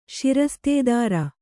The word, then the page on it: ಷಿರಸ್ತೆದಾರ: ṣirastedāra